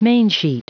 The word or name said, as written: mainsheet